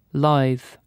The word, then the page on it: agile: France; [a.ʒil]